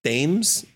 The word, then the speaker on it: Thames: I think that this kid Mason Thames, is that how you pronounce his last name? – Yeah, that's correct. – Yeah, it's not like the river.